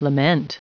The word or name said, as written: lament